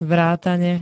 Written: vrátane

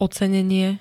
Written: ocenenie

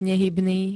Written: nehybný

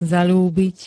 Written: zaľúbiť

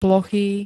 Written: plochý

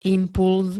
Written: impulz

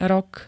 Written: rok

